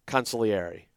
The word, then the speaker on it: consigliere: You don't pronounce the G. Consigliere.